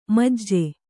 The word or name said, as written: ಮುಜ್ಜು